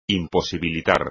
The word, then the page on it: imposibilitar: espagnol